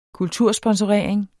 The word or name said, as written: kultursponsorering